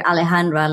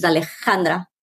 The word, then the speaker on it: Alejandra: So people say here Alejandra, like it with an H, and the way it's pronounced with a J, which is Alejandra.